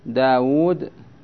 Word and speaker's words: داود: وكالواو التي يلفظ بها بين الواو والدال في داود، داود دا حركة بعدها ألف سكون، بعدها واو مضمومة حركة، بعدها واو تنطق ولا تكتب سكون.